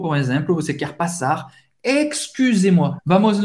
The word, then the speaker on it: excusez-moi: Ex, ex, tem son de x, x, x. Excusez-moi. Au U, et c'est U en française. c'est pronuncia U. U. Fais a boca do O et fala I. U. U. Excusez-moi. Excusez-moi. E Z, tem o som do E. Aberto. E. Excusez-moi. Outra frase essencial de sobrevivência.